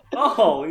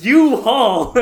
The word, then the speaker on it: U-Haul: And I'm like, you know, a-haul. You know, with the orange trucks and everything. And he's like, what? No, it's U-Haul. And I was like, holy shit, you're right. – It is pronounced U-Haul.